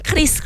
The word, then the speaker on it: QRIS: Tapi jujur nih ngomongin Kris ya, kan dari Bank Indonesia, dari banyaklah platform-platform juga di Instagram, di berita-berita sudah bilang bahwa si QRIS ini dibacanya kan Kris kan? – Kris.